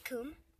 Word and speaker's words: cwm: There is a word, and it's spelled C-W-M. – You mean coom? – Yeah, coom. – Well, coom... It has a W in it.